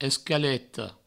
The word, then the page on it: escaleta: escaleto; provençal